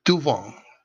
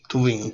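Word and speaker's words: Thauvin: Florian Toubon Florian Toubon yo sé francés, discúlpeme, Florian Toubon no se dice la U se dice como O, Toubon Toubon.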